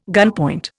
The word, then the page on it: gunpoint: Indian